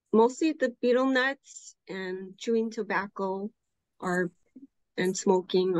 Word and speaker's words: betel nut: He's been doing research on people in the South Pacific who chew betel nuts.